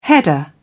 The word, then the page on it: header: British English